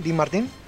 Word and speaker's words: Dean Martin: ¿Lo dije bien, Dean Martin? Las películas de Jerry Lewis. No, no se escribe Martín.